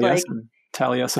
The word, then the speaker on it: Taliesin: I'd love to drive by that, because I've even been to the Frank Lloyd Wright School that's in Scottsdale, Taliesin or whatever it's called. – Taliesin.